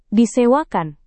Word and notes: disewakan: indonesian

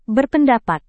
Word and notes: berpendapat: indonesian

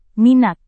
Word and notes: minat: Indonesian

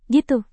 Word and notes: gitu: Indonesian

